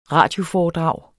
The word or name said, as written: RADIOFOREDRAG